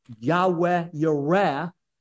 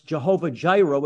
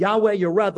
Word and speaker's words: Yahweh Yireh: And the revelation of God's name there is Yahweh Yireh, which most people pronounce Jehovah Jireh, which is not correct. But Yahweh Yireh, the Lord provides.